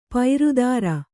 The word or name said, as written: ಪೈರುದಾರ